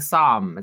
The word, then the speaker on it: Somme: Such films as The Battle of the Somme, is how you say it, S-O-M-M-E.